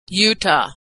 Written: Utah

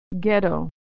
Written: ghetto

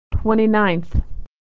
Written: 29th

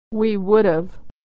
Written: we would've

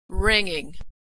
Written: ringing

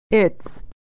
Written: it's

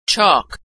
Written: chalk

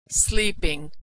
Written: sleeping

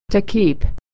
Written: to keep

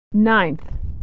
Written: nineth

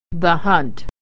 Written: hunt

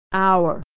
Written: hour

